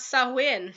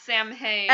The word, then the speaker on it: Samhain: So cut to 20 minutes later, is going to correctly pronounce Sawin. – Sawin. – Sawin. – Not Samhain.